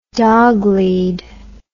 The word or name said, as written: dog lead